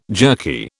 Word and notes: jerky: British English

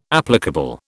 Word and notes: applicable: British English

